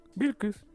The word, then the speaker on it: Bilquis: Sí, Bilquis.